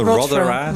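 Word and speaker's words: Rothera: Ja, want jij beschrijft onder andere het onderzoekstation uh, eh, Rotera, zeg ik dat goed? – Ja, nou, het is een Britse station, Rotera. Rotera, ja.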